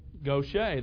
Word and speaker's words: Gautier: The town's name is Gautier. Gautier, that's exactly right. Most people don't know that, however.